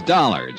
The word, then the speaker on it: Dollar: It's Dollar, Johnny Dollar. – Dollar, huh? – Yeah, it rhymes with collar.